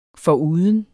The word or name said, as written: foruden